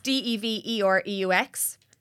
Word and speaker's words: Devereux: I have had every single like pronunciation under the sun. Devereux, like just, but it's Devereux.